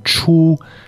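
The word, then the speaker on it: qù: So C-H-U, first tone, chu, and then Q-U, fourth tone, chu. – Exactly. – Okay, can you pronounce it a few more times for us? – Chu, chu, chu, chu. – Okay, and remember that's a Q-U, and the Q is not pronounced the same as the C-H.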